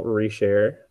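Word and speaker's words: Richerd: My name is Samuel Reshare. It's spelled Richard, but with an E instead of the A.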